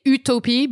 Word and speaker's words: Utopie: I mean, we say Utopie, but it's spelled like Utopia, but with an E at the end.